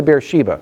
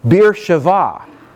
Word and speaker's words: Beersheba: So it's like saying from north to south, from Dan to Beersheba. When we say Beersheba, a more appropriate Hebrew pronunciation would be Beersheva.